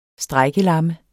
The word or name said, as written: strejkelamme